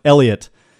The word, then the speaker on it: Heliot: It's pronounced Elliot, and even though it's spelled H-E-L-I-O-T.